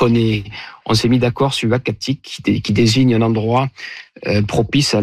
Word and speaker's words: Uhackatic: Alors, le nom, ça sera donc Uachatic. Et ce nom-là, donc, il s'écrit U-H-A-C-K-A-T-I-C, nous a été proposé par le conseil des Inuits d'Ekunitchit, qu'on a contacté justement pour avoir leur avis.